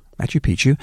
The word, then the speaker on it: Machu Picchu: Did I say that right? – Yeah. – Machu Picchu.